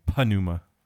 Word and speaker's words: pneuma: I think nice you have to pronounce the like P at the beginning, pneuma.